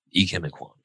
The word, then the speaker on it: Ikem Ekwonu: Ikem Ekwonu. Ikem Ekwonu.